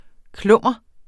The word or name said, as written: klummer